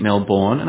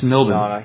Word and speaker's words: Melbourne: But I remember listening to a podcast he did a couple of months ago, and he was pronouncing it Melbourne, and I'm like, come on, bro. It's Melbourne.